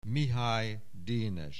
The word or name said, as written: Mihály, Dénes